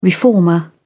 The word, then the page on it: reformer: British English